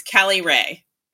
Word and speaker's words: Caliray: Is it Caliray? – No, it's Caliray. – Caliray. Okay, yeah. Got it. – It's like one word, Caliray.